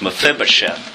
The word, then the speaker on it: Mephibosheth: How's it pronounced? Mephibosheth.